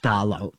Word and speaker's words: Phthalo: Fethalo is the name of the new album. I don't know how to pronounce it. But it is pronounced Thalo. P-H-T-H-A-L-O.